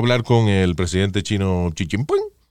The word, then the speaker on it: Xi Jinping: So Xi Jinping. – Bien. Xi Jinping.